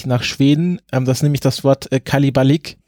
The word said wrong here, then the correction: Kalabalik: äh, Kalabalik, so ist es richtig ausgesprochen.